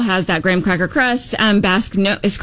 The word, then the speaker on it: Basque: This one is, did you say Basque? – Yeah. – Am I saying that correctly? – Yes.